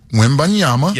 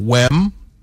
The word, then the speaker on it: Wembanyama: His name is Victor Wembanyama. – Yeah, there's an M, then an N, then an M. So Wembanyama.